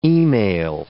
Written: e-mail

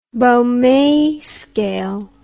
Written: baume scale

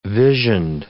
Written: visioned

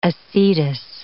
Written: acetous

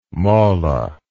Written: mollah

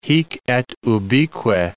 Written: hic et ubique

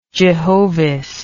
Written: jehovist

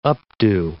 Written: updo

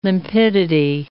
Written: limpidity

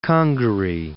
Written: Congaree

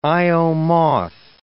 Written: Io moth

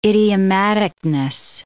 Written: idiomaticness

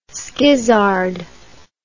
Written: skizzard

